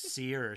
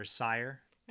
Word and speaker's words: sihr: It's spelled S I H R. – Believe in magic. – So it's pronounced seer or sire.